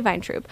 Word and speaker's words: Weintraub: Because my name has been translated from place to place and then into English and then tried to make sense in some kind of like German-Russian spelling, you know. And there, I don't know what the right way to say it. I just know how I say it. – Weintraub. – Weintraub. – Yeah, I say Weintraub.